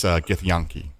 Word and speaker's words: Githyanki: I'm going to go 5 out of 5 just because I learned some stuff about D&D, and for the record, it's Githyanki.